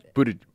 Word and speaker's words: Buttigieg: Did I say it right? – Buttigieg. – Buttigieg. – And Beto. – Oh, he wants the DGE sound?